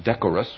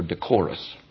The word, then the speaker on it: decorous: Quote, behaving according to a standard of what is proper, or you can either pronounce it decorous or decorous, and decorous means fitting to the occasion.